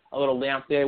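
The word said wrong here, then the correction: lagniappe: What is that? Is that how they say it, Rob? Lanyap. Okay, I tried.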